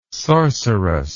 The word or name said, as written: sorcerous